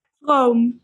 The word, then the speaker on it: Vroom: Vroom.